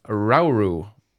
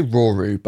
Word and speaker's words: Rauru: And Chris Hackney as Rauru. Is that how you spell it? – I say Roru, but... – Oh, Roru? – Yeah.